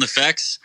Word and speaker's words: NEFFEX: But it's called NEFFEX, right? – Yep, it's called NEFFEX.